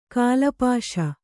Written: ಕಾಲಪಾಶ

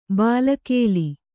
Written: ಬಲಿಗಲ್ಲು

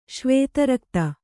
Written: ಶ್ವೇತರಕ್ತ

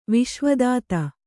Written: ವಿಶ್ವದಾತ

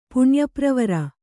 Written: ಪುಣ್ಯಪ್ರವರ

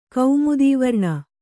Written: ಕೌಮುದೀವರ್ಣ

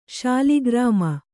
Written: ಶಾಲಿಗ್ರಾಮ

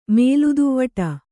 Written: ಮೇಲುದುವ್ವಟ

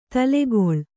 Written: ತಲೆಗೊಳ್ಳಿ

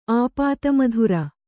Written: ಆಪ್ತಮಿತ್ರ

